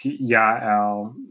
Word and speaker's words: Yael: So, and in this case, I've heard people render her name as JL, but I like Yael, the Kenite, at the end of this poem.